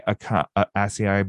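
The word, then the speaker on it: açaí: Strawberry acai bowl. Is it acai? How do you say that? – Acai. – Acai. That's what I want to say.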